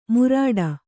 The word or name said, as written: ಮುರಾಡ